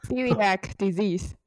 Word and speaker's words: celiac disease: 第一个疾病，它的名字很特别，它叫做乳糜泻，英文怎么念？Celiac disease 是这样念的吗？Celiac disease。